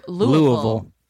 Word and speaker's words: Louisville: And he showed how to pronounce it. Louisville. – Louisville. – Yeah, say that with speech stuff. – Louisville. – You say you got a shot of bourbon in your mouth.